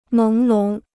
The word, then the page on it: 朦胧: méng lóng